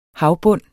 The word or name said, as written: HAVBUNDEN